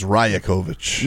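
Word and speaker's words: Rajaković: Let's start off with numero uno, Darko Rajakovic. Did I pronounce that correctly? – I think it's Rajakovic. – You know, you made it Latin.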